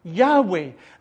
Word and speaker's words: Yahweh: The Hebrew word for this name is spelt YHWH, and according to current opinion, would have been originally pronounced Yahweh.